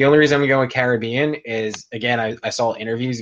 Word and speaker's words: Caribbean: Do you go Caribbean or Caribbean? Which is your preferred pronunciation? – So it's weird because I've heard it both ways. I've seen people associate it with the movie, call it Pirates of the Caribbean. So I go with that because I feel like that's the artist's intended pronunciation, if you want to do it that way.